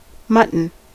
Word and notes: mutton: American English; [ˈmʌ.tən]

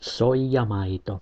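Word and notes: soijamaito: IPA: [ˈso̞ijɑˌmɑit̪o̞]; Finnish

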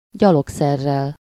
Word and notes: gyalogszerrel: /ˈɟɒloksɛrːɛl/

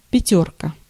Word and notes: пятёрка: /pʲɪˈtʲɵrkə/; pjatjorka

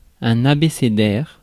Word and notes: abécédaire: France; /a.be.se.dɛʁ/

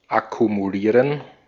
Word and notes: akkumulieren: /akumuˈliːʁən/; /a.ku.mu.ˈliː.ʀən/

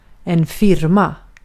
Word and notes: enterprise: American English; /ˈɛntɹ̩pɹɑjz/

